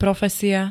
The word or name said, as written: profesia